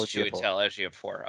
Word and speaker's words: Chiwetel Ejiofor: It is Chiwetel Ejiofor.